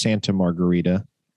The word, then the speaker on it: Santa Margarita: How do you say it? – I believe it's just Santa Margarita. – I've been saying Santiago Margarita. Santa Margarita Catholic.